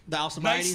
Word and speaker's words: Alcibiades: the Alcibiades. Nicely, nicely pronounced.